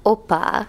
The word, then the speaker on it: oppa: So it's pronounced Oppa? Oppa? – Oppa.